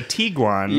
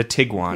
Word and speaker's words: Tiguan: Because I was calling it a Tiguan. – Yeah. – And you're like, actually, they prefer if you call it a Tiguan.